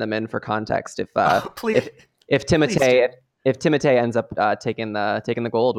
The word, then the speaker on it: Timothée: So I read an interview where Timothée is the correct pronunciation, but he doesn't want to come off like a dick, so he doesn't have anyone say that.